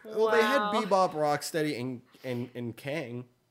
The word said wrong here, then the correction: Krang: Krang. – Sorry. Mispronunciation.